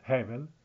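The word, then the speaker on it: Hevel (הבל): C'erano due fratelli, Cain e Abel, Hevel. I greci non potevano pronunciare la H e la V, ecco perché Hevel è diventato Abel.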